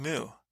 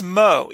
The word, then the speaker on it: Mu: He called her Ething Mu. Her name is Mo, even though it's spelled M-U. I mean, granted, it is one of the most unusual spellings, but I don't know if we should let him.